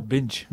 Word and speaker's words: Binche: J'ai bien prononcé, là, c'est bon. Avec l'accent, comment on dit en wallon avec cette prononciation? – Oh, Binche. – Binche.